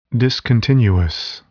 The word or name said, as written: discontinuous